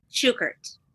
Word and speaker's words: Shukert: Shukert.